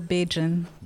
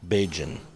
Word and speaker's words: Bajan: You know, like our nationality, they say Barbadian. Mm-hmm. Locals say we're Bajan. – Bajan. – Yeah, we're Bajan.